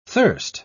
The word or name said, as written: thirst